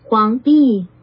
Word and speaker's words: Huangdi: Huangdi. Huangdi. – Huangdi. – Huangdi. – Huangdi. It's an upward followed by a downward descent of pitch.